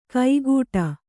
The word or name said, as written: ಕೈಗೂಟ